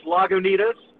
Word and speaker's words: Lagunitas: Truth, it doesn't matter how you pronounce the the actual way is Lagunitas, and we get Lagunitas, Lagunitas.